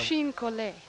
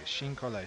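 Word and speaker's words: chine-collé: C-H-I-N-E-C-O-L-L-E with an apostrophe on it. Something like Chini Cole or something, or you always can do these things better than me. You pronounce English better than me, come to think of it. – So Chin Collet. – Thank you. Chin Collet.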